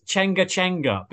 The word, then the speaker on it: Tianga Tianga: I believe that pronunciation is Chenga Chenga, because it obviously is written like Tianga Tianga.